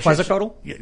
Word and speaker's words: Quetzalcoatl: And I've been to Quetzalcoatl? – That's how you say it.